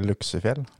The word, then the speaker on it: Luksefjell: Da er det ikke mange timene til vi uh, knytter skoa og hever oss i bilen og kjører til Juksefjellveien, eller hva som du uttaler det. L Juksefjell. Er det ikke Luksefjell? Luksefjell. Mm. Da kjører vi til Luksefjellveien. Ja.